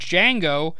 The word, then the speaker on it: Django: The main character named Django is pronounced without a D, therefore silent, but it's spelled out D-J-A-N-G-O.